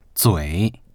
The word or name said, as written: zui3